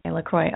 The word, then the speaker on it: LaCroix: Yes, it's L-A and then C-R-O-I-X. – That's LaCroix. What I thought. LaCroix, okay.